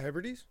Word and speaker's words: Hebrides: The Blue Men of Minch, however, were more similar to mermen and tried to drown sailors in the Outer Hebrides. – Huh. – Did he say that right? – Yeah, Hebrides.